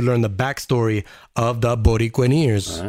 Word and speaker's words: Borinqueneers: Okay, I gotta pronounce this. Borinqueneers? Borinqueneers. – Ooh, that sounds good. – Like musketeers. Borinqueneers.